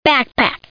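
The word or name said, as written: backpack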